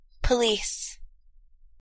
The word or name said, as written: police